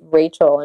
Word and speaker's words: Rachel: When I grew up in Germany, people read that very, very differently, because when you read Rachel in German, it's Rachel.